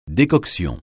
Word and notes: décoction: French

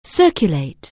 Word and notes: circulate: anglais

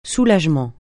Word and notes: soulagement: francais

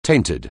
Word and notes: tainted: anglais

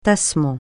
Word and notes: tassement: francais

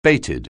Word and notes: bated: anglais

